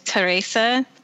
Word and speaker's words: Teresa: So it looks like it's spelled like Teresa, but the Japanese pronunciation is Teresa.